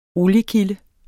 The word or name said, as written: oliekilde